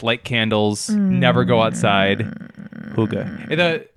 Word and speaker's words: hygge: No, it's hygge. I listened to an audiobook about it.